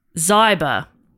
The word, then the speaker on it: Zyber: Next up, we have a fact quote question from Donna Zyber. Rhymes with cyber.